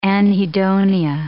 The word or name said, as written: anhedonia